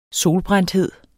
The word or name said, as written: solbrændthed